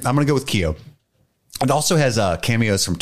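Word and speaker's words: Keough: Riley Cuff? Or is it Keo? – Keo, I think. I think it's Keo.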